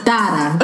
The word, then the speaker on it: Zara: Even Zara people. It's Dada. – Okay, bye, Miss European. – Am I right? It's Dada. – That's how the Spaniards pronounce it.